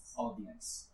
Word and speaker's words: audience: La AU se pronuncia como O. Audience.